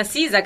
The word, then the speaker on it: SZA: Sì, sì, sì, con Sisa, che tra l'altro, bella ragazza. – Un filo. – Sisa, che invece ha fatto, che si scrive, per chi non lo sapesse, SZA, quindi Savona, Zanzibar.